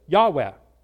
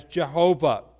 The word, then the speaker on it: Yahweh: If you were to hear it pronounced in a Jewish way or a Hebrew way, you would hear it like this, Yahweh, or Yahweh, or sometimes it's said as Jehovah.